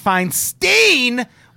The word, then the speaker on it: Feinstein: Max says, ATB, it's been driving me nuts when you call her Dianne Feinstein when it's definitely pronounced like Einstein.